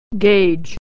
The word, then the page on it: gauge: e-ey